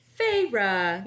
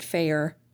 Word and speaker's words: Feyre: How do you pronounce the main character's name? – Feyre. – It's Feyre, yeah. See, whenever I first started reading it, I pronounced it Fair.